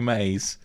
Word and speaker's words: Mays: What did I say, Mars? – You said Daniel Mars. – I mean, you could spell it that way, can you not? – No, I don't think so. It's Daniel Mays.